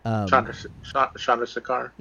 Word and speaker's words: Chandrasekhar: Chandrasekhar? – Yeah.